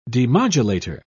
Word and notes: demodulator: British English

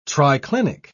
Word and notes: triclinic: British English

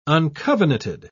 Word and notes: uncovenanted: British English

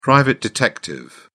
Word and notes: private detective: British English

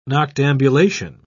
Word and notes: noctambulation: British English

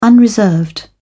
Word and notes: unreserved: British English

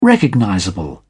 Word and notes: recognizable: British English

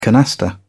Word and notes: canasta: British English

